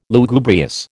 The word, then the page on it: Lugubrious: American English